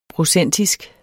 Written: procentisk

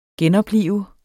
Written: genoplive

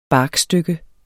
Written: barkstykke

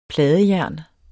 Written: pladejern

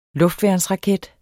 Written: luftværnsraket

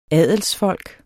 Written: adelsfolk

